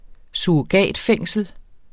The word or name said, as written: FORVANDLEDES